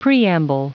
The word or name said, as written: preamble